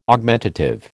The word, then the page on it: Augmentative: American English